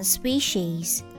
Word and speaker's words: species: And the next one, species. Species, species. Here the P sounds like b, b, species, species. And the last one, survive, survive. S-U-R pronounced as sir, sir.